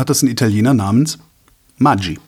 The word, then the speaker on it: Maggi: Und die hat sich für mehrere Sendungen, ging es äh, um die korrekte Aussprache von Maggi. Kennst du, ne? Dieses Würz. – Maggi die Würze. – Genau. Ja. Die mhm. anscheinend tatsächlich Maggi heißt. – Nein. – Was aber nur die Schweizer sagen.